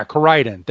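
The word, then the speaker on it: Koraidon: Coridon? Yeah, Coridon.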